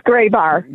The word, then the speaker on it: Grabar: It's Graybar.